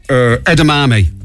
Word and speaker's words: edamame: You had to tell me how to pronounce... what's the name of those... those beans things that we had one time? – Beans? – What was it you called? – Chipotle. – Edamame. – Oh, edamame, yeah.